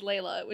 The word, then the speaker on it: Leela: It's spelled Leela, but it's pronounced Layla, which is bugging me.